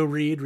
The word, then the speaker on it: Reid: I'm on Twitter at Joe Reed. Reed is spelled R-E-I-D.